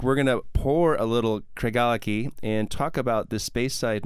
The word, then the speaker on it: Craigellachie: So, Craig, how do you say it? – Craigellachie. – Craig Allity, yes.